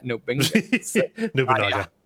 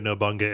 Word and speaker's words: Nobunaga: But like one of the cutscenes is you're basically like, you become like a samurai vassal under, was it Nobunaga? – Nobunaga. – Nobunaga. – Pronouncing Nobunaga Nobunga is a running joke on the network.